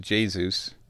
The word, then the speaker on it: Jesus: Jesus, although it's pronounced Jesus, comes off.